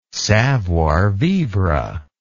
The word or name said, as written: savoir vivre